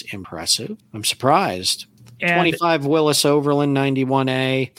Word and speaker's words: Willys: It matters how you pronounce it. It is spelled Willys through the history of the whole company, but the family name is pronounced Willis, John North Willis.